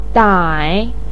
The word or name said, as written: dai3